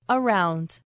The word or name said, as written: around